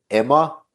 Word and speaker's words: Ema: It's called, if I'm pronouncing it correct, Emma, E-M-A.